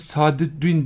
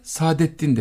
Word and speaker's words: Sadettin: Mesela biz Sadüddin demeyiz de Sadettin deriz.